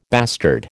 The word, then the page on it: bastard: American English